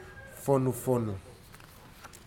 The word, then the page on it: fonufonu: futuna aniwa